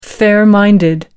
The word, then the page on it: fair minded: American English